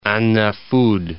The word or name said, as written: An Nafad